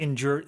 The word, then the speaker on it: endure: 뭐 버텨내다, 뭐 견디다, 뭐 오랫동안 지속되다라는 뜻인데 이 발음이 when you say endure, there's a little J in there, right? You kind of hear that Endure, yeah. – endure, right? I don't know what the exact 발음 기호 is, but just be aware of that.